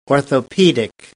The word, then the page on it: orthopedics: American English